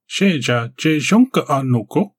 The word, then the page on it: Sheja-jezhonka-noko: Galindri